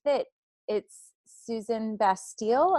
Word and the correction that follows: Suzanne Stabile: Who co-authored Suzanne Stabile.